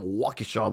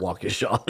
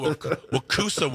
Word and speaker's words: Waukesha: I got Wakusa once. I'm like, there's the U is further in that.